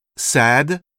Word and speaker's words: sad: Ainsi dans glad, sad, on entend le D comme dans le français salade.